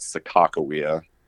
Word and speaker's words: Sakakawea: So the locals will always call it Sakakawea. It's kind of funny because it was supposed to be named after whether you refer to her as Sacagawea or Sakakawea.